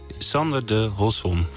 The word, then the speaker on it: Sander de Hosson: Je achternaam is steeds anders uitgesproken. Ja. Zeg jij nog even hoe het uitgesproken moet worden? – Sander de Hosson.